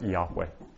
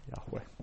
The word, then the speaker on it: Yahweh: Generally now it's recognized that most probably this personal name of God would have been pronounced as something like Yahweh. Yahweh.